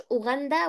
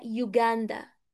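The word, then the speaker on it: Uganda: يوغاندن، يوغاندن، يوغاندن اسمها باي ذا واي يا جماعة، شوف حمدي قالها ازاي، هي مش أوغندا ولا أوغندا، هي يوغاندا ماشي.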